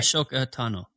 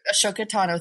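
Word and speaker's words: Ahsoka Tano: Ashoka Tano. – Ashoka Tano.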